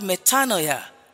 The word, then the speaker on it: metanoia: And so also the word repentance as used at Luke 24:47 is the word metanoia in the Greek. Metanoia in the Greek. It's spelled M-E-T-A-N-O-I-A.